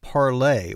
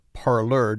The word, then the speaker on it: Parler: In the days since the general election, the app for the social media network Parlay, or Parler, depending on how you choose to pronounce it, has risen to the top of the charts on the app stores for both Apple and Google.